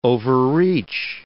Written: overreach

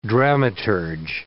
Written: dramaturge

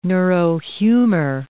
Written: neurohumor

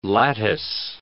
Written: lattice

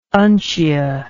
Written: uncia

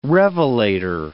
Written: revelator

